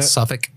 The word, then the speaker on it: Suffolk: I think the show was called I Love Suffolk. Suffolk? Suffolk? Am I pronouncing that? – Suffolk.